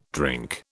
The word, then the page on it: drink: British English